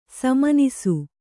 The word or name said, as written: ಸುಮನಸ್ಸು